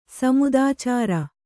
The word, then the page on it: ಸಮುದಾಚಾರ: Kannada